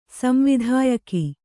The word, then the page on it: ಸಂವಿಧಾಯಕಿ: samvidhāyaki